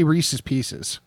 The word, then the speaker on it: Reese's Pieces: Oh, I say Reese's Pieces. – That's the correct way to say it.